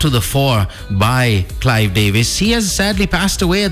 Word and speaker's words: Davis: I'm unsure why some of the bigger news networks haven't really shared this story, but turns out Clive Davies, or Clive Davis, as the Western Hemisphere calls him, I think that is in fact the way you pronounce his last name, Clive Davis.